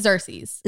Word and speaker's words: Xerxes: Xerxes.